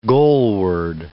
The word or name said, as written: goalward